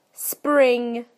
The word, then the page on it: spring: British English